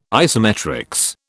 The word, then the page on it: isometrics: British English